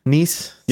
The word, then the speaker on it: Nice: Nice is probably how you say it. – Yeah, please. Yeah.